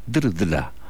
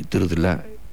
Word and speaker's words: Drdla: El compositor checo František Drdla. Es un apellido bastante difícil de pronunciar. Se deletrea D-R-D-L-A y se pronuncia así, como está escrito.